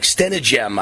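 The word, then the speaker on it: Stenehjem: Wayne Stenegem, it looks like Stenegem, I believe it's how it's pronounced, passed away unexpectedly at age 68 on January 28th, so just a couple of days ago.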